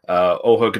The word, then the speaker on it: Oja: Written by Oja Kadar and Orson Welles. Did I pronounce her first name? – Oh, yeah. Oja Kadar.